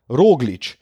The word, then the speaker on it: Roglič: tako kot Primoš, ampak se pa njen priimek izgovarja z oskim ojem, ne ro, ampak ro, roglič.